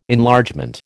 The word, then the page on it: Enlargement: American English